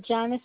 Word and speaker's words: Janice: Janice is the first name, J-A-N-I-C-E, and D-A-S-E-N-T is the last name.